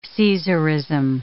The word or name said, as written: Caesarism